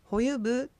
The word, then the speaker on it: ホユプー: ホユプー、走れという意味の文章です。このホユプーはホユブーと発音しても構いません。